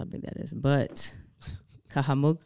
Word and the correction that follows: Cahokia: am I saying it right? Cahokia. See, I told you there was pyramids in Illinois, bruh.